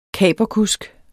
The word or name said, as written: kaperkusk